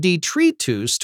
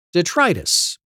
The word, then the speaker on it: detritus: After being imported into English in the late 18th century, its pronunciation has shifted from detritus to its English equivalent, detritus, but it's retained the same meaning of waste or discarded material.